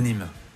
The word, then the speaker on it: Nîmes: Ho detto bene? – Sì, l'Arena de Nîmes. L'Arena di Nîmes.